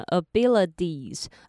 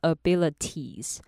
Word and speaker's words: abilities: 那接下来是 abilities 这个字，一样大家一定知道它的 T 要换成 D 的音，所以你要念成 abilities 而不是 abilities。